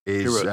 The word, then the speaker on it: Chirrut: I think you pronounce the name Chirrut. – Chirrut sounds right, yeah.